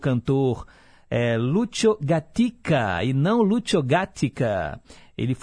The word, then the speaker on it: Gatica: A gente ouviu Sabre Dios, e eu mencionei aqui, né, fiz erroneamente a pronúncia gática, e na verdade é gatica. Lúcio Gatica.